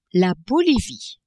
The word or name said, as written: Bolivie